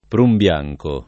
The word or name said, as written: prunbianco